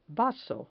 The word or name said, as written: vaso